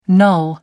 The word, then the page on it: null: American English